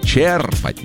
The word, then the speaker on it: черпать: Правильно ударение на первый слог. Этот вариант зафиксирован в большинстве нормативных словарей. Черпать на «е». И ударение на «е» сохраняется и в других глагольных формах.